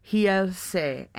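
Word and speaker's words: Realce: Yeah, the R's are H's, right? – Yes. – So I guess it'd be Hielse.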